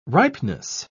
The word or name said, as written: ripeness